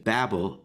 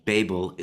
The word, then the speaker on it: Babel: Babel, which is the American pronunciation. Babel is the English pronunciation of the word.